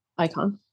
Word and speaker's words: Icahn: Icahn.